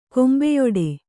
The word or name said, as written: ಕೊಂಬೆಯೊಡೆ